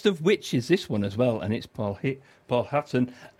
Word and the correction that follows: Paul Heaton: and, did I pronounce that right then? It's called Fish and Chip Supper, anyhow. Paul Heaton, perhaps, yeah.